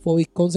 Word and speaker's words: PHOVIDCON: FOVITCON se escribe P-H-O-V-I-D-C-O-N.